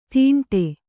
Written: ತಿಂಡೆ

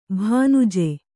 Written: ಭಾನುಜೆ

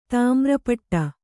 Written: ತಾಮ್ರಪಟ

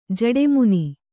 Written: ಜೇಡಿಮಣ್ಣು

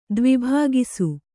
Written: ದ್ವಿಭಾಗಿಸು

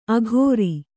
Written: ಅಘೋರಿ

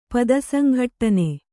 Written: ಪದಸಂಘಟ್ಟನ

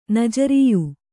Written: ನಜರೀಯು

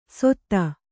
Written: ಸೊತ್ತ

